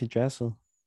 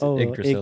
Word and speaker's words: Yggdrasil: I thought the GG you don't pronounce. – No, you do. Yggdrasil. – Really? I thought it was Yggdrasil. – No, it's Yggdrasil.